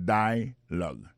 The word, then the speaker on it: dialogue: alors mot dialogue ça c'est dialogue Ça nous a remarqué là, nous a remarqué que l'accent tonique là tombé sous le premier syllabe là.